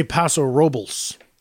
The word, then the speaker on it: Paso Robles: You know, the locals say Paso Robles.